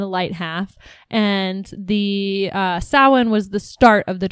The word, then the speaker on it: Samhain: You've probably heard or seen this word around somewhere, but it looks like Samhain. And it's pronounced Samhain. – Samhain.